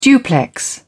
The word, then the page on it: duplex: British English